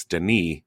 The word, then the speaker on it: Denis: The director, uh, I know, I know the first, I know the pronunciation of the first name that the S is silent. It's Denis uh-huh.